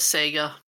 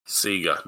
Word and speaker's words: Sega: Outrun was Sega. – Sega, not Sega. We're Australian, thank you. Say it properly.